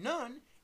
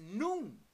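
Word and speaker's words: nun: And this is pronounced with the U sound in the word noon being long. So rather than none, it's noon.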